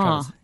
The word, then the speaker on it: Travers: Did I pronounce his name wrong? – No, that's right. Yeah, Peter Travers.